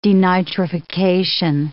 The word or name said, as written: denitrification